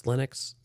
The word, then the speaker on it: Linux: I don't know how to pronounce Linux, so no.